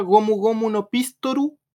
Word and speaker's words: Gomu Gomu no Pistol: En Japón se pronuncia gomu gomu no pistoru y así lo estaban pronunciando en esta película.